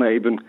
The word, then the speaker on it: Avon: Sagt man eigentlich on-Avon? Guten Morgen, Herr Sekulic. – Guten Morgen, Frau Bernasconi. Avon heisst es.